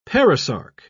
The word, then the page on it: perisarc: British English